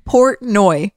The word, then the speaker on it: Portnoy: How do you say his name? Dave Portnor. – Dave Portnoy.